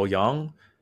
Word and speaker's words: Yeung: If you search Michael Yeung, my surname is, surname is spelled Y E U N G.